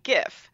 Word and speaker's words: GIF: It's G-I-F, pronounced like giraffe or Geronimo, GIF.